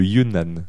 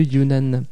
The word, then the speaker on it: Yunnan: Alors, prononciation officielle. – Attention, je te laisse faire. – C'est le Yunnan. – Le Yunnan.